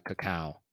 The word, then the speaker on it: cacao: Is actually pronounced like cacao. – Cacao. Oh, yeah, you're right. It is.